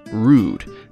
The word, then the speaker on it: Rood: Its title also uses an archaic word for cross, pronounced Rood, that's R-O-O-D.